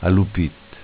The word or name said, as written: Alupit